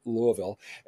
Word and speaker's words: Louisville: So I'm looking forward to new openings and to being in Louisville.